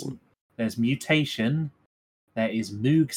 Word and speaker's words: Moog: I think it's pronounced Moog, as an aside. It's a brand of synthesizer.